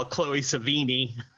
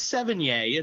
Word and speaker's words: Sevigny: And I was like, oh, it's Sevigny. It's not Savini.